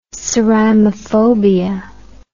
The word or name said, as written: ceramophobia